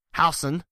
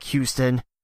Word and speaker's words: Housen: And number 4, Lehman Catholic, has number 9, Housen. Yes, I know it looks like Houston, but it's pronounced Housen. Trust me on this.